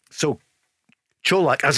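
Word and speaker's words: Čolak: How are we going to pronounce it? – It's definitely Colak. – Colak, okay. – Yes, and that's coming from good sources in Croatia.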